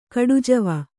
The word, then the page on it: ಘಟಜೀವಿ: kannada; ghaṭa jīvi